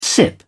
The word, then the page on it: sip: British English